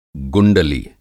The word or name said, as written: गुंडली